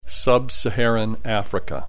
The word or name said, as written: sub Saharan Africa